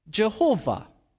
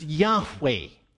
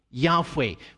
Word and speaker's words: Yahweh: But most scholars think today that, in fact, the name should be pronounced Yahweh. Yahweh, with just the two vowels added to the four letters.